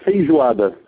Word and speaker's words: feijoada: We call it feijoada.